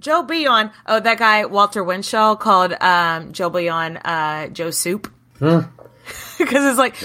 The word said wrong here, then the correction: Bouillon: Is that how you say it? The Bion? The Bouillon. – Bouillon. I'm sorry. I've been saying it wrong.